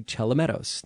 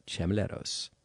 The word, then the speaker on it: Chelomedos: I mispronounced his name by transposing the L and the M, and it's actually Chelomedos, not Chemeleros.